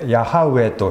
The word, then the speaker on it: ヤハウェ: 私は主であるというこの主というのは元の言葉でヤハウェというふうに発音できるような言葉です。契約を実現される神様の名前がヤハウェ、韓国語では常にこれをヨホワというふうに訳して主という言葉を避けています。特別な神の名だからです。で、この主なる神様のヤハウェ